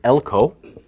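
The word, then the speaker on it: Elko (ἕλκω): The root is elko. Elko. Sometimes it's pronounced as elkuo.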